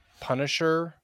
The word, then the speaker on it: Pwnisher: Lately, I have been enjoying a YouTube creator called Punisher, but spelled like Pwn, P-W-N.